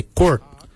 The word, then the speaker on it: goord: For heart, we say quart. The quart is your heart. Quart is spelled G-O-O-R-D. Quart. Quart is your heart. For kidney, we say jup. Jup is spelled D-J-O-B.